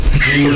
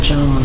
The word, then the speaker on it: Jean: Gene or something. Gene. – I think they pronounced it John, honey. – John. – Yeah, John, because it's his people from St. Lucia.